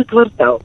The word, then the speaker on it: квартал: Правильно говорить, конечно же, в бухгалтерии аврал завершается квартал.